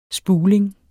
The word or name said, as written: spuling